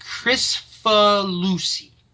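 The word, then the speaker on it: Kricfalusi: Well, actually, let's see if they have how it's pronounced. – Okay. – Yeah, it's Krikfalusi.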